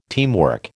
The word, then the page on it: teamwork: American English